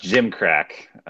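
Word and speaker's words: gimcrack: It's pronounced gym crack.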